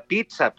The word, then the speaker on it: pizza: pizza se pronuncia pizza, pues, ¿no? Hay, hay, eh, no, no quisiera ser como esos este, youtubers molestos que dicen, no se dice así, se dice así, pero es que pisa no, así, así no funciona, Jairo.